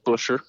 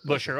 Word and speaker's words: Buescher: It, it's just Busher. – Busher.